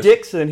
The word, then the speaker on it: Dixon: You know, in this town, if you say Dixon here, you've got to say D-I-X-O-N.